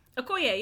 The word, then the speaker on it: Okoye: Okoye, yeah.